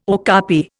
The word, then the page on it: okapi: Indian